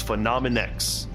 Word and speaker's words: Phenomenex: It's spelled P-H-E-N-O-M-E-N and then a big capital X, Phenomenex.